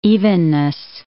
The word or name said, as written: evenness